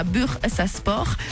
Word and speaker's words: Bursaspor: Enfin, en sport, en barrage aller de la Ligue des Champions, Anderlecht a fait un grand pas en s'imposant 1-2 face à Bursaspor, qui est champion de Turquie.